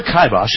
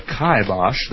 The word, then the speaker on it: kibosh: So, the word kibosh has been with us for over 150 years. It is pronounced either kibosh, the first syllable is in rye, or kibosh.